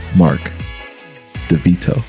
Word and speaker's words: Mark Devito: I am here with the homie, Mark Devito.